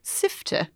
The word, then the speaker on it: Sift: Actually, if you're going to give it its Swedish pronunciation, it's Sifter.